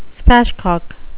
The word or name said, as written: spatchcock